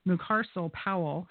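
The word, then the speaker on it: Mucarsel-Powell: I think that, so it was just now called that Representative Debbie Mercosso Powell, and I always mispronounce her name, Mucarcel Powell, and that's in the 26th congressional district in Miami.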